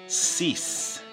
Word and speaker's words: Cease: June 24th, 1957, saw the birth of Jeff Cease. I think that's how it's pronounced. Cease, C-E-A-S-E. He